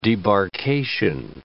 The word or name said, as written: debarkation